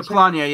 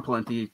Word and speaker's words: Polanyi: Polanyi? Is that how you? – Yeah, Polanyi. Yeah, I call him Palinti because I mispronounce everything.